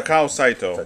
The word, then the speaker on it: Takao Saito: In manga, the world lost another great legendary manga creator, and that is the creator of GoGo 13 himself, Takesito. Yeah, is it? Did I pronounce his name wrong? – Yeah, I did. – Okay, I'm sorry, but Saito. Well, because it continues. Yeah, Takao Saito. There we go.